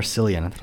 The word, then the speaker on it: Cillian: Oh, was it Cillian or Cillian? – I know for a fact it's pronounced Cillian.